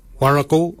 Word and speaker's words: worragol: I-N-Y, barken. Barken is your beard. The upper mouth, we say warrigal, warrigal. Warrigal is spelled W-O-R-R-A-G-O-L, warrigal.